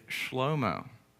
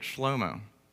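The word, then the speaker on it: Shlomo (שלמה): Solomon's name in Hebrew is Shlomo. That's how you say it, Shlomo.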